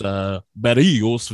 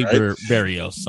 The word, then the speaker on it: Berríos: No, that's the proper. Yeah, trying to roll your R's. You're trying to feel pay respect. – I feel bad. – It feels a little weird. – Jose Berríos. I'm going to say it very anglicized.